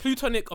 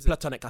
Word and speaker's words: platonic: So is it plutonic or platonic? – It's platonic, I think. But I think it's spelled plu. – Oh, P-L. Yeah. Oh, it's pla. – Yeah. P-L-A.